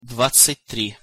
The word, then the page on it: dvadcat- tri: Russian